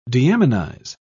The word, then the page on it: deaminize: British English